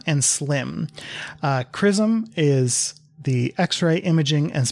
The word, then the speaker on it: XRISM: Please believe me, it's pronounced CRISM. The acronym is X-R-I-S-M, and it's either CRISM or CRISM, and that is not what it is, right? I know it starts with X-ray and should not be pronounced K, but that's what we're going with.